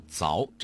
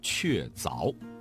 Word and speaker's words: 凿: 类似的修改还有不少，比如确作这个词，最新版的新华字典里已经统一了凿字的读音，它只有凿这一个音，得念确凿。